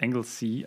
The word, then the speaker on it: Anglesey: Weil dieses, wie auch immer es geschrieben wird, Ang oder wie es ausgesprochen wird, Anglesea, also wie quasi Angelsee, das ist entweder eine anders geschriebene Sache, die es im Vereinigten Königreich äh, gibt, die heißt dann Anglesey mit Y.